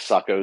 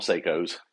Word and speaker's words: Sako: I guess Sako is the correct pronunciation, but I live in the South and we call them Seikos.